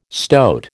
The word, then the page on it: stoat: American English